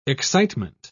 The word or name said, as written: excitement